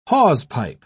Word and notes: hawsepipe: British English

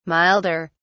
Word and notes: milder: British English

